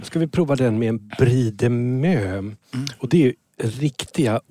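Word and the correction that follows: Meaux: Är det meux? Är det inte må? – Må uttalas det såklart.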